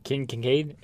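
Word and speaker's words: Keith Kinkaid: So, but here's what I here's what my idea is, and you may believe it in King Kincaid. Did I still say that wrong? – Keith Kincaid.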